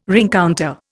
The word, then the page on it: rencounter: Indian